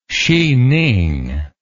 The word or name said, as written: sining